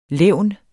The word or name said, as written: levn